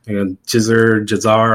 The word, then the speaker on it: Gissor: But just reach out to me on Instagram, G-I-S-S-O-R. Jizzar, or whatever you want to call it. – How do you pronounce it? – Tell you the truth, it's just Ross C. J. backwards.